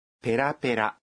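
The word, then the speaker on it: بيرا بيرا (ペラペラ): ولكنها تستخدم أيضا لوصف شخص يجيد التحدث بلغة أجنبية نعم ولكن النبرة تختلف إذ تنطق هكذا بيرا بيرا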